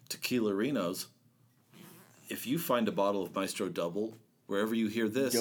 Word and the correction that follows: Dobel: Dobel. – You need to clear your glasses. There, push them up the bridge of your nose. Here, clear your throat. – It's pronounced Dobel.